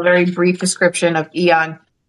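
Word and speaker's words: AEON: Yes, Eon.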